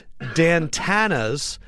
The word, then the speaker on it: Dantana's: We are going to be at Dantana's in the CNN Center. That is Dantana's in the CNN Center. – Rhymes with Santana. – But it's Dantana.